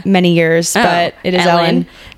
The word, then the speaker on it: Elin: She discovered Elin Hildebrand on this trip. Is that not how you say her name? I thought that was Ellen. – Ellen. – Oh, is it just Ellen? – It's Ellen.